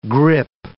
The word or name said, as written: grippe